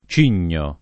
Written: cigno

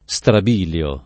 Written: strabiliare

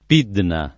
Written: Pidna

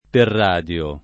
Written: perradio

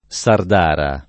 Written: sardara

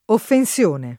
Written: offensione

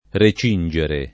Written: recingere

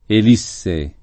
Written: elisse

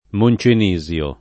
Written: Moncenisio

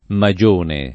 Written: magione

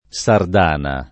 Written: sardana

